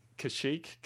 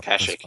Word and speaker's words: Kashyyyk: Kashyyyk? – Yeah. – Kashyyyk.